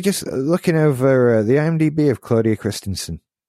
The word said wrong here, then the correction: Christian: Christian. I added extra vowels to her name, extra syllables.